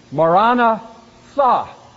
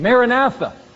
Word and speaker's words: Maranatha: It was literally pronounced Maranatha, which says, O Lord, come, in the Aramaic language. But we tend to say it Maranatha.